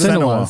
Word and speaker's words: Senua: Is that what Senua.